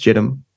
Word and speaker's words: Jitwam: It's spelt Jitwam, like Jitwam, but I think it's pronounced Jedim.